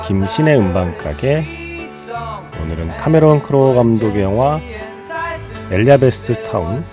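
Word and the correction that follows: Elizabethtown: 네, 발음이 잘 안 되네요. 엘리자베스 타운 사운드 트랙 함께 했습니다.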